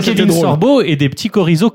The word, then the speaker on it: chorizo: D'abord, on dit chorizo. Chorizo Fafner, si vous voulez.